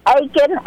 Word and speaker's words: Akin: Aiken, A-K-I-N.